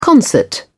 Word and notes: concert: British English

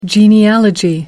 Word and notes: genealogy: American English

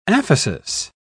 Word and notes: aphesis: British English

